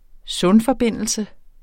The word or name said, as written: sundforbindelse